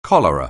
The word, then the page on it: cholera: anglais